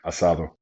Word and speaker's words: asado: I don't think Michael was there, but Jason was there, a couple other boys, his brother, and we had asada. – Asado. – Is that how you pronounce it? – Asado. – Okay, so I'll pronounce it Australian way, asada.